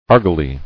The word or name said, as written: Argali